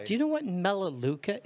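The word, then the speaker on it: Melaleuca: And he goes, are you familiar with Malaluca? – No. – And so I tell him, funny enough, I am familiar with Malaluca. – That's I've always heard it pronounced Melaluca,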